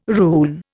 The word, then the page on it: rune: Indian